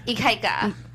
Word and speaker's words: Ikaika: How do you say his name again? IKEA? – Ikaika. – What was it? – Ikaika. I think it means strong in Hawaiian.